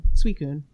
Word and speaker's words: Suicune: Suicune.